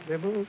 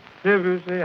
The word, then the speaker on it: Debussy: It is not Debussy, but how then? – Debussy.